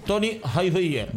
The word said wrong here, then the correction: Tommy Hilfiger: Tommy Hilfiger.